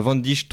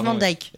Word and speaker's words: Van Dijk: Euh, Van Dijk troisième pour moi déjà. – Van Dijk, hein, euh, c'est Van Dijk.